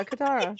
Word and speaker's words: Katara: Yeah, Katara.